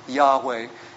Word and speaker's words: Yahweh: All Bible scholars, you know that it's really not pronounced Jehovah. It's probably Yahweh.